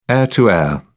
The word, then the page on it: air to air: British English